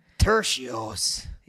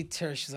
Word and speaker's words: Turcios: I'm just like Turcios. – Yeah, Ricky Turcios.